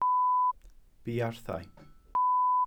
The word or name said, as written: buarthau